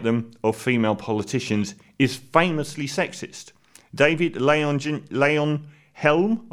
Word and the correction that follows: Leyonhjelm: I never say his name right, David Leyonhjelm,